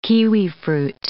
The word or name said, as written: kiwifruit